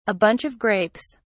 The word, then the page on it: (a bunch of) grapes: British English